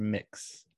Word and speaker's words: Mx: If you don't know, MX is like a gender neutral salutation rather than Mr. or Mrs. It's pronounced mix.